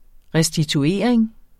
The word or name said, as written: RESTITUERING